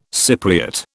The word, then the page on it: Cypriot: British English